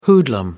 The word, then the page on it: hoodlum: American English